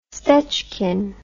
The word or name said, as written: stechkin